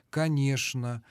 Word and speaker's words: конечно: Ну, десяток, может быть, полтора слов, в которых требуется произношение «ш» на месте буквы «ч». Это «что» и его производные, «конечно». Это скучно. – Скучно. Ну, вот яичница, булочная. – Двоечник. – Ну, двоечник. Но булочная уже иногда произносит и так, и так.